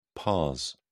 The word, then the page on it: pars: British English